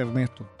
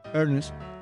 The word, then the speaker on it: Ernest: Se pronuncia igual que la palabra Ernest, con A después de la primera E, que significa severo o serio.